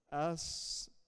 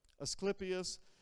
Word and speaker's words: Asclepius: Another thing that was there was the temple of, I have to pronounce it correctly, Asclepius, there we go, Asclepius, and he was known for his temple of serpents.